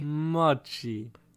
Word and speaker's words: Mochi: Mochi.